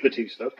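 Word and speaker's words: Bautista: It's Dave Batista, but well, his name is spelt differently. It's spelled B-A-U-S-T, but it's still Batista.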